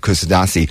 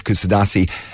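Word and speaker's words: Kuşadası: You literally walk 3 minutes down the road and you're into the centre of Kusadasi. And it's great, because actually I saw some footage there recently of Kusadasi, should I say. I always pronounce it wrong.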